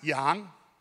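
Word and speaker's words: yang (羊): This is the Chinese character for sheep. It's pronounced yang.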